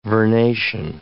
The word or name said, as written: vernation